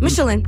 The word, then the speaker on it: Michelin: I love how you say Michelin, by the way. – How do I say it? Michelin. – There you go.